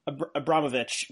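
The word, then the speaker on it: Abramović: Abramowitz. Forgive me if I'm saying that wrong. You can pronounce it correctly. – Abramowitz, yeah.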